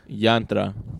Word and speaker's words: Yantra: How do you pronounce this? Jayatra? – Yantra. – Yeah. Oh, what is it, Spanish? – No, it's Indian. It's an Italian wine, but it's an Indian name.